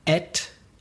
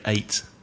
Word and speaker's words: ate: Naturally, I would say eat, et, eaten. Would you say the same? – No, I'd say ate. – I thought that I was waiting for you to pronounce it to see how you pronounced it. So two possibilities.